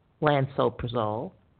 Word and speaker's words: lansoprazole: And looked at four of the most popular proton pump inhibitors, omeprazole, pantoprazole, lansoprazole, and esomeprazole.